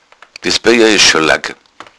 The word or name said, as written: lagg